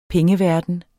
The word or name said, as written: pengeverden